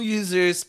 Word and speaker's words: YoUSers: No, yung users parang it's spelled as Y O U S E R. Parang you, ikaw, then sa salita na yon may US pa rin, parang AS and then ER. Users. Ganon.